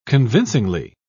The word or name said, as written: convincingly